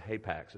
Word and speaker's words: hapax: I think I say this word hapax. It's spelled H-A-P-A-X, which means it only shows up once in the text.